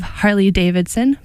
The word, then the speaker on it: Harley Davidson: Next up, we have Harley Davidson by Brigitte Bardot, except she pronounces it Harley Davidson.